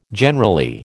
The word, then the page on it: generally: American English